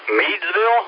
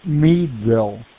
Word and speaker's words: Meadville: I'd like to welcome Todd, KN3PKJ, in Meadsville? Is that it? Or Meadville? Medville. How do you pronounce that, sir? – That is Meadville.